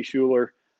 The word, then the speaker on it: Schueler: Just punch in Richie Schueler. Last name is spelled S-C-H-U-E-L-E-R.